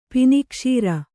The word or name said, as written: ಪಿನಿಕ್ಷೀರ